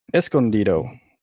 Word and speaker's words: Escondido: Encendido. – Sure. We'll go with that. – How do you pronounce it? – He won't sue us. – That's Escondido. That's how you say it.